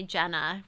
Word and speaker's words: Gena: So you can find me Jenna, which is spelled G-E-N-A, LaValle.